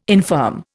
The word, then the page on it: infirm: Indian